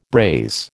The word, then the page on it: braise: American English